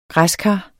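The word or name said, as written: græskar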